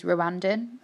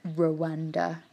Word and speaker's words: Rwanda: He's part Ugandan and part Rwandese, which is Rwandan. I didn't realise that they actually don't say it like Rwanda like we do. It's more like sort of all in one, like Rwan.